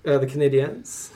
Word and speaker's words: Canadiens: The Canadiens. – That's correct. Okay. – Objection. She didn't pronounce it right. – The Canadiens.